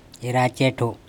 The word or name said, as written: eracEtho